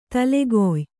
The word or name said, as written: ತಲೆಗಾಯಿ